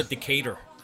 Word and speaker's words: Decatur: Ja, det udtaler så Decatur. – Decatur. Fordi man skal være altså, mid-American, ikke?